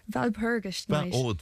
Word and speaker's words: Walpurgis Night: Walpurgis Night. – Val, of course, it's a V-E-L because it's German.